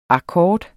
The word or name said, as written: akkord